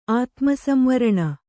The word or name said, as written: ಆತ್ಮಸಂವರಣ